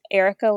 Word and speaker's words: Erica: You can find me on Instagram, Erica Lee Photography.